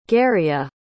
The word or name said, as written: garia